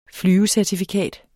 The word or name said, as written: flyvecertifikat